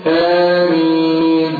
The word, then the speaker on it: آمين: ونطق صحيح فيها لو عايز نطق صحيح فيها ما تقولش وتقول مين آمين هكذا أقول الفاتحة على بعضها يا اخوانا وطبعا يا اخوانا الفاتحة بالذات محتاجها واحد وقت يقرأها صراحة يعني.